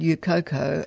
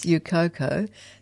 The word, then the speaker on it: EUCOCO: Yes, it's always called UCOCO. – I was going to say that, but I thought I'll say it wrong. – Yeah, no, no, it's known as UCOCO, has been going since the beginning of the conflict.